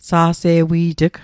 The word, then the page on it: sa'se'wi't'g: Mi'gmaq